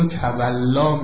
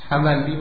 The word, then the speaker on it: تولی: اول کلمه تولی که ما گاهی این رو تولا میگیم و غلط به پارسی تلفظ میکنیم، نقطه مقابل اون تبری است. تولی، تبری، گاهی ما این رو پارسیش میکنیم، میگیم تولا، تبرا. نه، تولی و تبری قرائت صحیح کلمه است.